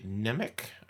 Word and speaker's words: Nemec: And it was written by Josh Applebaum and Andre Nemec, I guess is how you say his name.